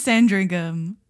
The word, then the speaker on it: Sandringham: I think it says Sandringham.